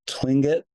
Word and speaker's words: Tlingit: But before you left to go to Glacier Bay, all of you met with a woman from the local, I don't know if I'll get the pronunciation right, but Tlingit tribe. – Very close.